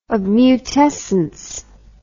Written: obmutescence

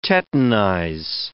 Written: tetanize

